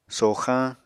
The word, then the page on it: soxañ: Apatani